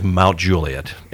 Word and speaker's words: Mount Juliet: He is the public information officer for the city of Mount Juliet, an incredible city.